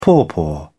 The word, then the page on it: pawpaw: British English